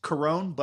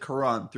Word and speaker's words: Cuarón: Yeah, actually, it's Cuarón, but I pronounce it Cuarón throughout the episode.